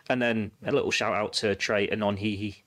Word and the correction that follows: Nyoni: in I've said that wrong. What's his name? Sorry, Nyoni, uh, who uh, we gave a shout out on the Q and A, didn't we, earlier this mm.